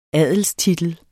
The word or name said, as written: adelstitel